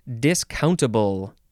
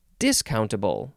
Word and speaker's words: discountable: Let's move on to discountable. Discountable, you can emphasize the second syllable or you can emphasize the first syllable, which sounds like discountable.